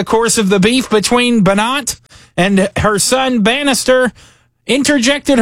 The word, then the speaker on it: Banat: Banat. B-A-N-A-T. – That's Banat, the way you pronounce his name.